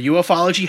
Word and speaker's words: ufology: but the kind of the UFOlogy community or ufology. How do you say that? – Ufology. – Ufology. I should have known that.